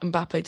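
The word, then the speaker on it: Mbappé: An outstanding performance from Kylian Mbappe, to be honest. Some stunning goals that he scored, obviously ruled out by offside and all of that. But it's a shame that Mbappe doesn't get to progress further.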